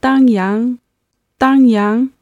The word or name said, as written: dangyang